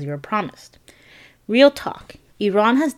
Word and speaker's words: Iran: Iran is not pronounced Iran. It's pronounced Iran.